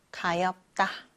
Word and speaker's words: 가엾다: 가엾다는요. 비읍 하나만 남아서 가엾다 이렇게 소리가 나요. 역시 다가 아니라 따가 되었죠.